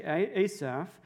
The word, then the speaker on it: Asaph: You can pronounce it differently in the Hebrew. Generally, we say Asaph.